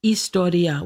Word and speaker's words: historia: The lowest level is called historia, with an H-I-S-T-O-R-I-A. Historia. The Greeks don't pronounce the H.